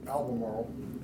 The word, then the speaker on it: Albemarle: While he was pastoring, he was instrumental in the founding of the, how you pronounce that, Albemarle? – Albemarle, North Carolina.